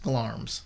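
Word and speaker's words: Glamis: Yeah, I had to look it up, and it's pronounced Glarm's.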